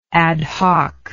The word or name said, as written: ad hocness